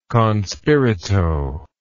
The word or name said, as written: con spirito